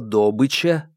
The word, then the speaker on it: добыча: Горняки произносят слово «добыча» с ударением на первый слог. Такое ударение в русском языке не очень принято, но горнякам можно.